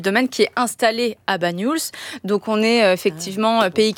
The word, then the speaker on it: Banyuls: Comment on le prononce ? – Banyuls. – Banyuls. – Oui, ouais. C'est ça.